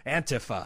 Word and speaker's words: Antifa: You had Antifa. Am I saying it right? I only know it by a hashtag. – Yeah. – Antifa.